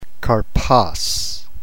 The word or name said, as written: karpos